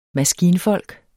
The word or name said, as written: maskinfolk